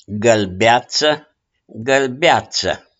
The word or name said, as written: GĂLBEAȚĂ